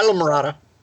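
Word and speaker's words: Islamorada: I hope people that are interested in spearfishing in the Florida Keys check in and see you at Killshot Spear Guns in Isla Morada. Did I say that right? – Yep. Close. Yep. Isla Morada.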